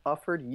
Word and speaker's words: Ufford: My last name is spelled Ufford, U-F-F-O-R-D.